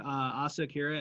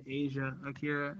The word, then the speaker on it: Asa Akira: One time I interviewed Asa Akira and I said Asia Akira, and she definitely was mad.